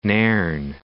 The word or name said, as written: Nairn